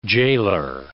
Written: jailor

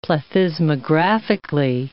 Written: plethysmographically

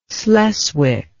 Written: sleswick

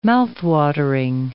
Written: mouthwatering

